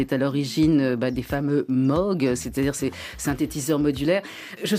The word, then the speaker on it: Moog: Ensemble, vous avez sorti un ouvrage sur Robert, alors attention pour la prononciation, Moog ou Moog. – Alors lui, il disait Moog like Vogue.